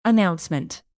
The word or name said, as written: announcement